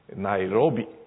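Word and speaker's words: Nairobi: それでケニアの言葉は全部全部です、例外なくおしまいから 2 番目のシラブルがアクセントがあるんです。ですからとても発音が分かりやすい、ナイロビ、ナクールという感じであります。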